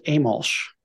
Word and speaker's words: Amolsch: It's pronounced Amolsch.